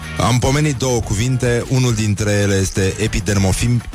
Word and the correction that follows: epidermofiția: epidermofiția. Scuze, prima dată l-am pronunțat perfect, da.